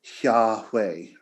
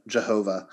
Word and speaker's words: Yahweh: But if you were just to pronounce those, they are Yahweh, right? Or in the German, Jehovah.